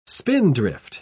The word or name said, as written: spindrift